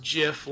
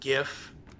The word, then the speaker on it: GIF: About the creator of the GIF coming out saying it's pronounced GIF like the peanut butter, not GIF.